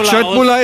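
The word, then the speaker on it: köttbullar: Cetbula ist die richtige Aussprache.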